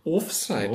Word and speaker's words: Offside: Betonung auf der ersten Silbe. Offside. Offside.